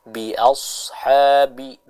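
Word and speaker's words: bi-ashabi (بأصحاب): Contohnya, bi-ashabi, bi-as, bi-ashabi, ya, bukan bi-as-ha, tapi bi-as, bi-ashabi, seperti itu.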